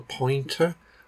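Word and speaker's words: Pinter: But also as well, anyone who has watched one of my videos, they'll see that Jane bought me this thing for Christmas called a pointer. My pronunciation's terrible. It's P-I-N-T-E-R.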